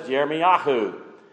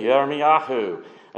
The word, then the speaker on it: Yirmiyahu: In Hebrew, his name is pronounced as Yirmiyahu. Okay, Yirmiyahu.